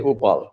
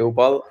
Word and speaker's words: Uppal: But my last name is pronounced like you're saying oops. So as long as you can say oops, you can say Uppal. So it's Sanjay Uppal.